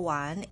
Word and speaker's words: 玩: The pinyin for "wan" is W-A-N with the second tone above vowel A. It's pronounced as "wan," "wan."